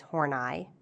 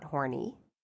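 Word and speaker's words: Horney: It's Horneye, not Horny.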